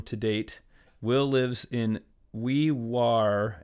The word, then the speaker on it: Wee Waa: as he tells me to pronounce it but it's it's written Wee Waa in New South Wales, Australia. Will writes, I live in a remote outback town in New South Wales, Australia named Wee Waa, pronounced Wee Wah.